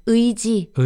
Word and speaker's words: 의지: Check the show notes for the spelling because 의, this letter, this syllable is very often not pronounced as 의. It's often pronounced as other things like 이 or or eh. But in this case, it's often just 의지.